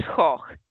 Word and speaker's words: Gogh: he's Dutch, it's Gogh, you're mispronouncing it in the French way, just no.